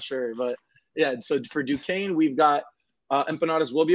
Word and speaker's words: Duquesne: Is it Duquesne? – Duquesne, yep.